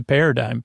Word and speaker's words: paradigm: Par, so I shifted the paradigm. P A R A D I G M. So don't spell it dime, even though it's said dime.